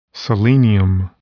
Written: selenium

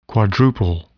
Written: quadruple

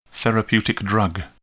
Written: therapeutic drug

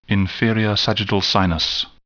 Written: inferior sagittal sinus

